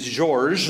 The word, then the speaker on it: Georges: His name is Georges, the French pronunciation, and he sells toys.